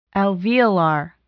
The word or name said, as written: alveolar